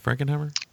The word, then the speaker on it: Frankenheimer: Am I saying that right, Frankenheimer? – Yes, sir.